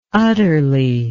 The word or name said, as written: utterly